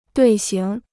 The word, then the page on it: 队形: duì xíng